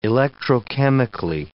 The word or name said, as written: electrochemically